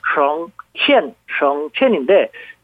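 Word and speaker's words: 省钱: 우리말로 성전 중국어 발음은 삼성과 사성이었고 성첸 성첸인데 돈 전략도 좋지만 이런 식으로 하면 안 되는 거 아닙니까?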